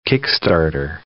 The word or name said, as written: kick-starter